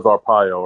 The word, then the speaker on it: Arpaio: Is that how you pronounce it? Sheriff Arapo, the sheriff that Trump loved? Joe Arapo. – Oh, yeah, yeah. – I think it was Arpaio. Or Yep, that's right.